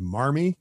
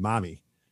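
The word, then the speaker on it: Marmee: It went by, it looks like it would be pronounced Marmy, but these are all New Englanders. So that R is going to be an A sound. So it actually is pronounced Mommy.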